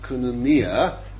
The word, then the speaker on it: koinonia: In your book, it's put koinonia, or it's better pronounced koinonia. Wherever you read the I in the Greek, the emphasis is on the E and not I. Koinonia always.